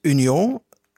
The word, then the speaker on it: Union: Union spreek je dan gewoon wel uit Union, zowel in het Frans als het Nederlands, met een U en niet met een OE.